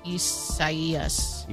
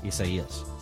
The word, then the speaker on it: Isaias: Well, no, but it's probably the Spanish rendering of it, which in case, and that's if that's the case, then the I is pronounced like a E. – Isaias. – Isaias.